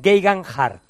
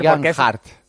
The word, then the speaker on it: Geoghegan-Hart: Theo Geoghegan-Hart. Lo ha colgado alguna vez en su Twitter. – Geoghegan-Hart. – Sí, su nombre es Tao, pero se pronuncia Tello, su apellido se pronuncia Geoghegan y el segundo Hart.